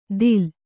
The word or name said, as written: ತಿಲ